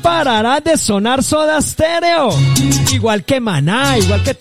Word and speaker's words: Soda Stereo: No con E, sino que así se pronuncia, Soda Stereo. Pero es con S, Soda Stereo.